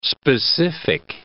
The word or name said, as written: specific